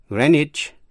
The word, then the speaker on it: Greenwich: Chữ Greenwich có hai cách đọc. Trước hết, âm E có thể đọc là I, âm W câm, và CH có thể đọc là CH hay là J. Vậy có thể đọc là Greenwich, Greenwich.